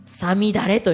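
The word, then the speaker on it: 五月雨: はあはあ、 NSP 5 月雨と書いてさみだれと読みますね。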